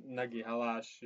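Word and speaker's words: Nagyhalász: However we say it, Nagyhalas, yash, yash, yash. In translated, is big fish. – Wow. – The meaning is big fish. We are racing at big fish. That's how you say it.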